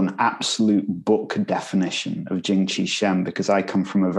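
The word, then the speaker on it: Jing Qi Shen: So it's Jing Chi Shen. – Jing Chi Shen. – Although some people say Ying Chi Shen, but I'm from Manchester, you know. we pronounce things differently there.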